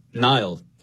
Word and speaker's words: Niall: When I was growing up, I would say Nile, like N-I-L-E effectively, like the river.